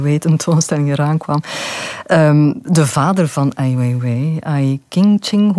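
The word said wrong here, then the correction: Ai Qing: hoe moet je het uitspreken? – Dus Ai Ching is de vader van Ai Weiwei.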